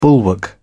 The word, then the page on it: bulwark: British English